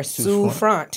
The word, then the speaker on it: Souffrant: It's really easy. It's just Sue. Like, I'm going to sue you. And then front, the front door. Souffrant.